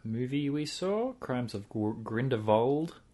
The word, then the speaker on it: Grindelwald: One thing I learned There's a V in it. I was just Grindelwald, and everyone was really putting the emphasis on the V in the movie.